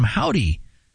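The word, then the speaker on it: Hovvdy: That after this one from Howdy, spelled with two V's, H-O-V-V-D-Y, but it sure looks like a W.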